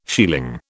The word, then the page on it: shieling: British English